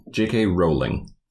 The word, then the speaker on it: Rowling: It took me a bit to research this, but JK's last name rhymes with bowling and not howling. – JK Rowling. – Yeah. – Your joke is funnier if you use the... – like rolling balls.